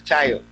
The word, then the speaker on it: Tayo: Matthew Tayo. Matthew Tayo is fine, no problem.